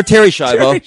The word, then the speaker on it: Schiavo: Yeah, you got Teresa Schrivero. – Teresa Schrivero. Or Terry Schiavo.